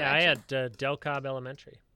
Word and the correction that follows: DeKalb: Or DeKalb, I should say. I should say it like it's actually pronounced, not like a rube.